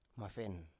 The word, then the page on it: mafen: Biak